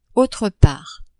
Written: autre part

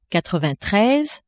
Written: quatrevingttreize

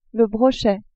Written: brochet

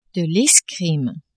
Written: escrime

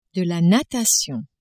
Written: natation

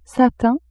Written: satin

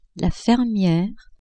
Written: fermiere

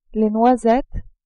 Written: noisettes

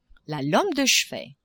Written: lampe de chevet

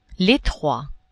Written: etroit

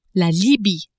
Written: Libye

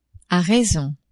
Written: a raison

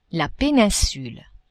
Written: peninsule

